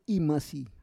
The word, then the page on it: imasi: Roon